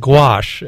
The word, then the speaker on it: gouache: Well, the way you pronounce it, I'm not familiar with it either. But it's called gouache.